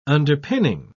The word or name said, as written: underpinning